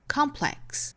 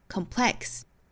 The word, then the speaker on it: complex: 那这里呢这个词也是哈，英音的话是读成 complex，重音在第一个音节 complex，美音呢是读成 complex，按照视频里面这个女孩读的哈，应该重音在后面了 complex。